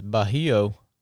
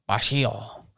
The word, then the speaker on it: Bajio: How do you say these sunglasses here? – I think it's Bajio. – Bajio. B-A-J-I-O sunglasses, because my Leopold sunglasses failed me on my paddle.